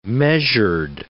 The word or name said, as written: measured